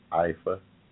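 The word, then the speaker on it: Ifá: I'm going back uh, looking into my my uh, ancestors of uh, religion out of Nigeria, the Yoruba, Alpha, I, I pronounce it alpha, L F I A, uh, alpha, IFA, IFA, uh, who uh, worship the god Olodumare.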